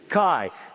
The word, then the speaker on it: chi: Sometimes you'll hear the word key come out of my mouth. That is not how you pronounce it. It is chi. It's like the letter X.